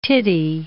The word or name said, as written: tittie